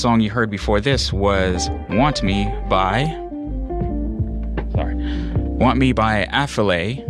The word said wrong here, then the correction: Affelaye: Actually, I feel like I'm pronouncing that wrong. That is... Sorry, Afilaye. There we go.